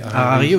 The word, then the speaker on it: Harari: Ah bah, moi j'aime bien euh, Yuval, euh, je sais jamais comment le prononcer, Harari. Harari, oui, ça, ouais.